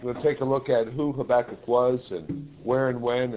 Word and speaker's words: Habakkuk: Some pronounce it Habakkuk. Others pronounce it Habakkuk, and Scorby pronounces it Habakkuk. Therefore, it is with the most rigorous insistence that we recommend Habakkuk.